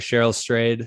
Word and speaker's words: Cheryl Strayed: I'm trying to remember how to pronounce her name, but Cheryl Strayed. Is that how you say it? Okay. Yeah.